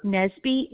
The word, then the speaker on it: Nesby: It's K-A-C-I-E. – Okay, and your last name? – Nesby, N-E-S-B-Y.